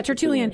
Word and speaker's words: Tertullian: But is it Tertullian? Is that the other? – Yeah, Tertullian.